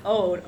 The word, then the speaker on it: Odd: His first name is pronounced, I think, in Norwegian, Ode, O-D-D, Arne Westad, or Vestad, W-E-S-T-A-D. Vestad has